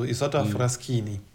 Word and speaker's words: Isotta Fraschini: Wirklich, also das CHI wird KI ausgesprochen, also Isotta Fraschini.